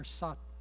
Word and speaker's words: Sartre: Let me start with, there's a guy named John Paul, he's a philosopher, 20th century philosopher, French philosopher, I don't know how to say his last name, S-A-R-T-R-E, Sartre, or Sartre, any French people? Sartre.